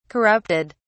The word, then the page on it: corrupted: British English